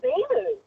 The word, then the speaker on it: Beelu: Probably Beeloo. – Beeloo? – It's around Derry. But when I say Beaulieu National Park, nobody knows where it is. – How do you spell it? – B-E-E-L-U.